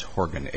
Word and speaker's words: Horgan: Yeah, it's Horgan, H-O-R-G-A-N. – H-O-R-G-A-N.